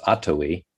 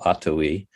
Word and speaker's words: Atowi: And so the name that I came upon was an Abenaki word that's it's pronounced Atowi, accent on the first syllable, Atowi.